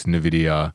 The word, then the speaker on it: NVIDIA: Outside of COVID, are there any other, you know, you mentioned the microchips, stocks, NVIDIA.